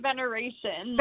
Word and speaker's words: Veneracion: It's actually veneration. Like celebration.